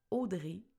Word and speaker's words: Audrée: Et là, peut-être que tu apprends que mon vrai nom s'écrit Audrey, A-U-D-R-E accent aigu, et là, ça, ça fonctionne. Audrey Trudel, tout simplement sur Instagram.